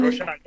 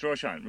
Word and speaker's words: Roshan: Am I pronouncing your name right? Roshan? – Roshan, yeah, that's my name. – Roshan, yeah. I didn't know if it was Roshan or Roshan. – It's Roshan.